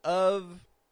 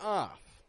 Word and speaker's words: of: As I mentioned, of is either pronounced of with a V sound or with a schwa, but never off. That is O-F-F, and that is a totally different word.